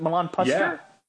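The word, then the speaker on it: Milan Puskar: Milan Pusker? Am I saying that right? Milan Pusker? – Yeah, I think that is right. That's 100% correct, sir.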